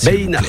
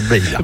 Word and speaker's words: Beina: Je me permets juste la petite rectification d'entrée, Christophe. – Oui. – Beina. – Beina. Ah oui, il faut bien prononcer le Beina. – On prononce correctement. – Comme pour Faïtia, c'est Beina.